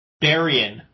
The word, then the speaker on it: Barian: So how is the pronunciation again? – Barian, with an N.